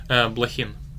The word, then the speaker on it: Blokhin: That's the Latin pronunciation. – Okay, there you go. And the non-Latin pronunciation? – Blokhin.